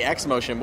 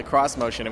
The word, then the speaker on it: cross motion: Cro- I want to say X motion, but we were corrected that it's the cross motion, and we want to say it right.